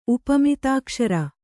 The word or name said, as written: ಉಪಮಿತಾಕ್ಷರ